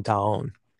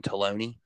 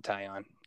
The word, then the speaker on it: Taillon: And because you did see a lot more of him before a lot of people did, in a guy like Jameson Taillon. – Not shocked. And as we like to call him at Power Park, Jameson Taloney. they messed that up pronunciation wise, and I was quick to get him on track.